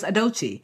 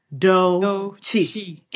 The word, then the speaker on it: Adochi: Like, my name's Adochi. After a month of hearing people butcher it day in, day out, teachers, friends, like, Odicha, I'm like, literally, it's Adochi. It's three syllables, super simple. And I said, I actually wanted to take it back.